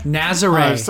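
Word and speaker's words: Nazaré: Nazare.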